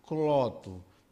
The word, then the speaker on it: Cloto: Cloto. Mas a pronúncia lá do grego é cloto.